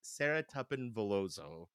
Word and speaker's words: Sara Tuppen Veloso: But a dear old university friend, Sara Tuppen Veloso.